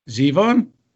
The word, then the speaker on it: Zevon: I thought the way the show opened was really good with the Warren Zevon song, of course, Lawyers, Guns, and Money. – I'm sorry, how do you say his last name? – Zevon? – Okay. You just said it like Bruce did at the show.